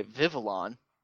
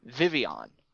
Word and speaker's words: Vivillon: He was constantly pronouncing it the American way, Vivillon, which is how we would read it. But we forget that there is some French pronunciation that we have to incorporate into it as well, which is actually Vivian, and it comes from the combination of two words, vivid and papillon, which is the French pronunciation of butterfly.